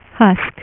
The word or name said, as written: Husk